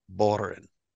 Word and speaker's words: Bohren: I'm going to spell it for you. – Yeah, thank you. – B as in boy. – Uh-huh. – O-H. R, E as in Edgar, N as in Nancy. You should have Bohren.